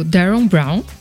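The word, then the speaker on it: Derren Brown: E a dica de hoje é um documentário esse chamado efeito placebo, que é do Darren Brown. Falei certo, amor? Falou. O marido é bom em inglês, gente. É, do Darren Brown, ele tava no Netflix, acredito que ainda esteja.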